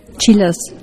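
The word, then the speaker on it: Csillas: Und damit wir es jetzt ganz sicher finden, Chillas spricht man es aus, aber geschrieben wird es Cäsar Siegfried, Ida Ludwig Ludwig, Anna Siegfried. Happy Designs.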